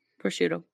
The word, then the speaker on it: Prosciutto: Prosciutto.